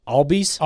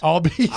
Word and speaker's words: Albies: Ozzie, he plays for the Braves, IBS? – Albies? – Albies. – Ozzie Albies.